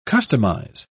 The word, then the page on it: customise: British English